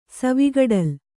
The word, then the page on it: ಸವಿಗಡಲು: kannada